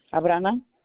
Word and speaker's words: Abrana: Abrana. – Can you spell that for me, please? – A-B-R-A-N-A.